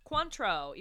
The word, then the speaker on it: Cointreau: That's how we're going to say it. Cointreau, even though it's spelled Queen Trial.